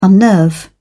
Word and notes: unnerve: British English

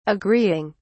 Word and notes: agreeing: British English

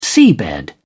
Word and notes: seabed: British English

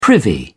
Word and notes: privy: British English

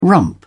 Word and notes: rump: British English

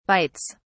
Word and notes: bytes: British English

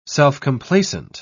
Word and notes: self-complacent: British English

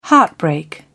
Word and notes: heartbreak: British English

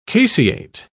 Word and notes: caseate: British English

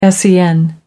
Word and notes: sen: American English

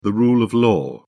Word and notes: rule of law: British English

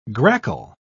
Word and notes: grackle: British English

